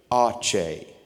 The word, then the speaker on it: Aceh: I thought, how on earth do you even say A-C-E-H? Who are the people, A-C-E-H? I went to YouTube to find out how to say it. Ache. Say it with me. Ready? Ache. Try it again. Ache. A-C-E-H.